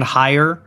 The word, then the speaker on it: Haier: Like the Chinese brand Haier, H-A-I-E-R. Am I pronouncing that one right? – You are.